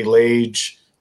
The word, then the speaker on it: Lage: Just very simply my name, which is Larry Lage, like Cage or Page, L-A-G-E is how it's spelled.